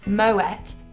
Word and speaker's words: Moët: Starting off with trivia, did you know that Moët and Chandon is pronounced Moët because the founder Claude Moët was of Dutch heritage? – I did.